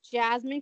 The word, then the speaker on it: Jazmin: Anyways, you can find me at Jasmine Christine, and it's how you spell my name, J-A-Z-M-I-N. – J-A-Z-M-I-N.